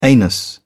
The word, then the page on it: anus: British English